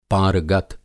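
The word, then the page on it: पारगत: pāragat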